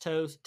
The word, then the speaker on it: TOSE: I think it's pronounced Toes, T-O-S-E.